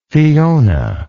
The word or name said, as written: fiona